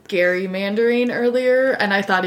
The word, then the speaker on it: Gerry: While the pronunciation has changed over time, Gary's name is pronounced like I'm saying it, Gary, but I think we all know we now say it like Jerry.